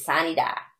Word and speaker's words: sine die: But in Arkansas, how we say it here, and this every legislator, everybody says it like this, is sine die. Sine die. It's spelled SINEDIE. So look it up. In Latin, it's sine die.